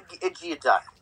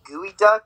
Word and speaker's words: geoduck: Now, you might ask yourself, what is a geoduck? First of all, it's not even pronounced that. It's pronounced like gooey duck. I don't know how to pronounce it. I said geoduck.